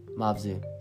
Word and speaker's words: Mavzu: And we might even be saying it wrong. That would be really funny. – Mavzu. – No. Is that how you say it? – M-A-V-Z-U. Mavzu. – So that's how we say it. Or the podcast is called Mavzi. – okay. – The word is Mavzu.